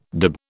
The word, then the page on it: dib: British English